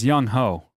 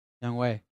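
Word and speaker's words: Younghoe: It's Young Ho. – Young Wei. – I have Young Ho. – Yeah, but it's pronounced Young Wei.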